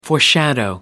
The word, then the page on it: foreshadow: American English